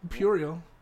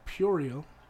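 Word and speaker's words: puerile: We actually, a lot of people pronounce it Puriel, and that's incorrect.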